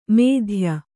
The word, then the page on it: ಮೇಧ್ಯ: mēdhya